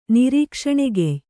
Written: ನಿರೀಕ್ಷಣೆಗೆಯ್

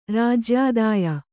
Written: ರಾಜ್ಯಾದಾಯ

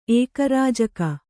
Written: ಏಕರಾಜಕ

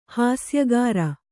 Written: ಹಾಸ್ಯಗಾರ

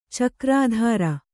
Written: ಚಕ್ರಧರ

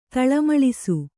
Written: ತಳಮಳಿಸು

